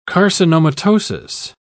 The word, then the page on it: carcinomatosis: British English